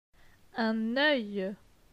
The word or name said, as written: Un oeil